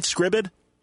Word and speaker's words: Scribd: Now, am I saying it right, Scribd? – It's actually pronounced Scribd. – All right, Scribd. – S-C-R-I-B-B. – All right, then. There we go.